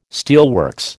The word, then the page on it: steelworks: American English